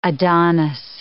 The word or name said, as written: Adonis